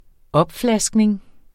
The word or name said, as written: opflaskning